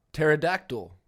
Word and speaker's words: pterodactyl: Pterodactyl. That's got a P before it.